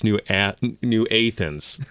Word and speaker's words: New Athens: So as I'm going through, I shake Dr. Meyer's hand, Dale Meyer's hand, he says, it's pronounced New Athens, not New Athens.